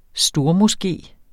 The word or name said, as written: stormoské